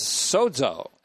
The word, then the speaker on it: sozo: And so this verb boethio or boethio belongs to soteriology, so does epilambano, so does sozo, S-O-Z-O, long O.